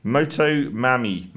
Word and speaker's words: Motomami: Motomami.